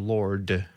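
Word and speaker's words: Lorde: And there's a cover of Royals by Lordi. Or Lord. – Lord. – She pronounces her name.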